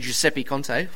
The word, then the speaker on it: Giuseppe Conte: Giuseppe Conte.